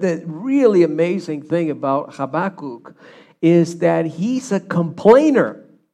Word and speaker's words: Habakkuk: The way you pronounce this in Hebrew is Habakkuk. Okay, you try that with me. Habakkuk. Yeah, you got to do the ch in the beginning, right? So just he's the kook, okay?